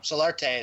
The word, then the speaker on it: Solarte: Solarte is how you say his last name.